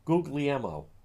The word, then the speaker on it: Guglielmo: And Guglielmo, hold on. Why do I keep mispronouncing his name? And I say that because I don't like when people mispronounce. Guglielmo, that's it.